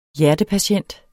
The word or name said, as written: hjertepatient